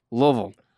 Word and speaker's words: Louisville: You have to say, you have to just relax the jaw and say, Louisville. Louisville. That's how you say it. Louisville. – It's one soul.